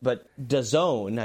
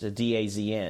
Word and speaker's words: DAZN: I don't know how new adding the women is, but DAZN, I believe is how it's pronounced, DAZN.